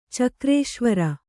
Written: ಚಕ್ರೇಶ್ವರ

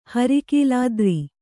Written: ಹರಿಕೀಲಾದ್ರಿ

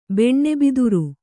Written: ಬೆಣ್ಣೆಬಿದುರು

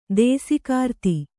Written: ದೇಸೆಗಾರ್ತಿ

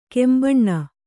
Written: ಕೆಂಬೊನ್

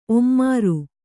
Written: ಒಮ್ಮಾರು

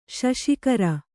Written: ಶಶಿಕರ

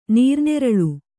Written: ನೀರುನೇರಳೆ

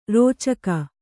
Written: ರುಚಕ

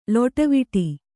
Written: ಲೊಟವಿಟಿ

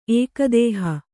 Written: ಏಕದೇಹ

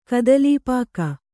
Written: ಕದಲೀಪಾಕ

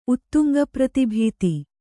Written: ಉತ್ತುಂಗಪ್ರತಿಭೀತಿ

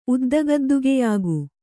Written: ಉದ್ದಗದ್ದುಗೆಯಾಗು

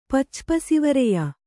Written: ಪಚ್ಚಪಸಿವರೆಯ

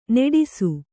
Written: ನಟಿಸು

